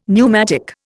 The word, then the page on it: pneumatic: Indian